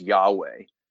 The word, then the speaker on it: Yahweh: If you're looking, if you're watching and you see YHWH, I'm going to pronounce that as Yahweh.